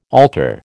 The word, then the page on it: altar: American English